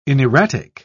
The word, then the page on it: inerratic: British English